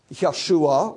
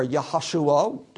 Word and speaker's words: Yeshua: Yeshua, or Yahashua, depending on your pronunciation, means God saves, God the Savior.